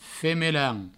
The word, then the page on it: femelan: Provençal